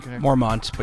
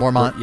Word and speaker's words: Mormont: Did I pronounce that correctly? – Mormont, but you're... – Mormont, yeah. Thank you.